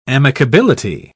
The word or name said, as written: amicability